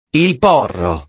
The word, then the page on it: il porro: italiano